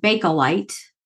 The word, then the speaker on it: Bakelite: It's actually pronounced Bakelite.